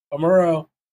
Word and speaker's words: Amuro: Amuro? – Yeah. Amuro.